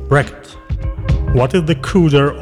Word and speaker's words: cdr: Note, CDR is pronounced CUDA.